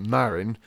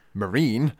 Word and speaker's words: Marin: I mean, case in point, the second main character we've got here, Marin, or as is constantly mispronounced in the Deke dub, Marine.